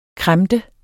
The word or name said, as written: kremte